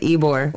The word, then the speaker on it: Ybor: Everyone from Florida is so mad about my pronunciation. I got Dimitri, poor little white boy, and Sir Mocha, Florida. – Sorry. – They're all like, Ybor. – Why the hell? Okay, we got it. Ybor.